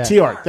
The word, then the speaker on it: Theart: and ZP Tiert. Did I say it right? I'm trying to get it right. Is it Tiert? Did I say it right? Tiert. – Tiert, yeah. – Tiert.